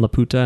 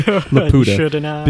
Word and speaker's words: Laputa: Sorry, when they actually end up landing on Laputa and they're... I shouldn't say it like that. I should say Laputa. Because Laputa just sounds like I'm calling somebody a whore.